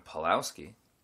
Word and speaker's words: Pawlowski: I'm your host, Warren Pulaski. Ah, I said my name wrong. I'm your host, Warren Pawlowski.